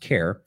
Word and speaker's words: Cahir: Caher Castle, looks like Cahir Castle, but it's pronounced Care.